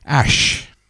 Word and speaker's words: AJ: It's sort of got AJ on it, and in brackets, it's pronounced apparently Ash.